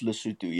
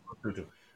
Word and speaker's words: Lesotho: It's pronounced Lesotho, yes. Lesotho.